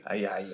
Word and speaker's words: Ajayi: Ajayi. yeah. Yeah, oh, the Ouija. Ajayi.